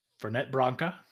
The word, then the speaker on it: Fernet Branca: Ferent Branca? – Fernet Branca.